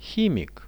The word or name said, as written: himik